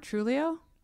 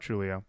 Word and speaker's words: Trujillo: They do recruit a new bassist after this record is recorded, and he has been with the band since, and I apparently have been mispronouncing his name. So, if you want to say how his name is supposed to be said. – Robert Trujillo? – There it is. I know the last time I said it, you both were like, sure. And so I was like, I'm clearly saying it wrong. So, Robert Trujillo.